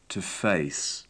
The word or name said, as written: to face